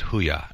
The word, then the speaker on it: HUIA: But there's one I saw, it was HUIA, and I'm like, well, how do you say this? And then somebody said, oh, it's, anyway, I talked to a rep, like, oh, it's Huya.